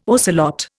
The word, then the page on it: ocelot: Indian